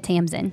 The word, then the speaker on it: Tamsin: So I listened to the audiobook and she said Tamsin.